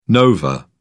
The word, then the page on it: nova: British English